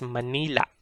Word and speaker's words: Manila: So instead, we pronounce it Manila, Manila. So there's a glottal catch at the end, Manila.